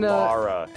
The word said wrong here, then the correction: Lara: Lara, be sure. – Yeah, goddamn. – Don't ever say that wrong, or I hope you die.